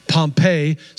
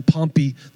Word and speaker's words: Pompey: 63 BC, 63 years before the birth of Jesus, Pompey, some people call him Pompey the Great. You may hear it pronounced both ways.